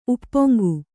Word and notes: ಉಪ್ಪೊಂಗು: uppoŋgu

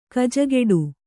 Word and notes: ಗೊಜಗುಟ್ಟು: gojaguṭṭu

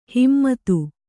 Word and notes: ಹಿಮ್ಮತು: himmatu

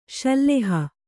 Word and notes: ಶಿಲಾಹಿ: kannada

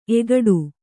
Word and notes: ಎಗಡು: egaḍu

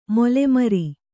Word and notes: ಮೊಲೆಮರಿ: mole mari

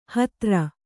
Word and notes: ಹುತ್ತರಿ: huttari

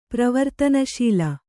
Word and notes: ಪ್ರವರ್ತನಶೀಲ: pravartanaśila

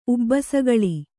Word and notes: ಉಬ್ಬಸಗಳಿ: ubbasagaḷi; Kannada

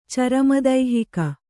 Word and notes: ಚರಮದೈಹಿಕ: carama daihika; Kannada

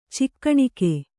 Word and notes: ಚಿಕ್ಕಣಿಕೆ: cikkaṇike

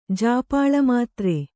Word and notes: ಜಾಪಾಳಮಾತ್ರೆ: jāpāḷa mātre

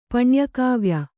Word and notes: ಪಣ್ಯಕಾವ್ಯ: Kannada

